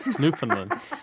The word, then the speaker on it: Newfoundland: I probably mispronounced that, too. – Newfoundland. – Newfoundland. – Newfoundland. – I've been corrected okay.